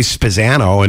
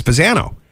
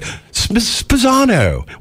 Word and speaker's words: Spezzano: Like everybody pronounces my name wrong. I say Spezzano, and it's Spezzano. But then everybody's like, Spezzano. What's up, Mr. Spezzano? I'm like, I don't correct him.